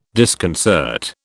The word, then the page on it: disconcert: British English